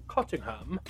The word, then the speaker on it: Cottingham: I'll spell that, because I'm sure there's different ways to pronounce his name. It sounds English or British. – Mm-hmm. – It's C-O-T-T-I-N-G-H-A-M. Cottingham.